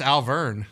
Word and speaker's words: Auvergne: Were a syllable off. It's just Auvergne.